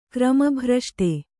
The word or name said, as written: ಕ್ರಮಭ್ರಷ್ಟೆ